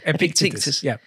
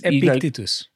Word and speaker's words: Epictetus: Epictetus. Well, now you pronounce it. You tell me how you pronounce it. – Because I think it's Epictetus.